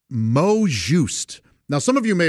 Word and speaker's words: mot juste: Mot juste. It's spelled M-O-T, and then the next word is J-U-S-T-E. Mot juste.